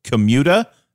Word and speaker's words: Commuta: So that a short commute, but the 1967 Commuta, am I pronouncing that correctly? – You are.